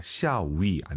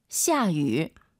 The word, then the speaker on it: 下雨: 샤위 안 됩니다. 비 오다 발음 다시 들어보고 싶어요. – 샤유. – 샤유. 샤유. 꼭 기억하시고요. 수 오가 아니라 굳이 한글로 표기하면 수어예요. 수어이. 네.